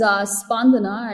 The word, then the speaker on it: Spandana: My name is Spandana, and then for those of you, like, it's pronounced like a bandana with an S and a P.